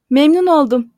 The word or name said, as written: memnun oldum